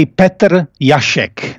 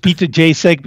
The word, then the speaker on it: Petr Jasek: Yeah, actually we say Petr Jasek. That's how we pronounce it. But you can stay with Petr Jasek because everybody calls me like that in America.